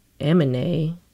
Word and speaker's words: Aminé: This next song is Dapper Dan by Aminé. Yeah, that's how you say it.